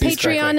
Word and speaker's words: Patreon: If you want to support Too Scary Didn't Watch, hit up their Matreon. I mean, we mean their Patreon. We're just, or yeah, again, I can never pronounce it's Patreon.